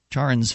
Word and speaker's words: Charon: Charon's Ferry? How do you pronounce that? C-H-A-R-O-N. – I've always used Charon.